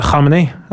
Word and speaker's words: Khamenei: Men jeg kan faktisk uttale det også, så jeg må skryte av Khamenei. Altså, den Og du kan si her? – Ja, det som egentlig ganske lik skarring, har jeg funnet ut.